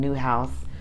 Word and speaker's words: Newhouse: and then Newhouse. Like, I bought a new house.